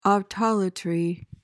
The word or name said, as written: autolatry